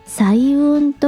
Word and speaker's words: 彩雲: 彩雲のお話をします色彩の際に雲と書いて彩雲と読みます